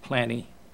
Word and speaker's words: Pliny: And this was AD 120, Pliny the Younger, I believe I've heard that pronounced Pliny, P-L-I-N-Y, the Younger,